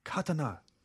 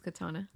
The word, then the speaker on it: katana: Did you know that a katana blade is actually pronounced katana. – No, I thought it was just katana.